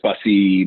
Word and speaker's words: Bussey: It's Chris Bussey, mortgage loan officer.